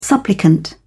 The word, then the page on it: supplicant: British English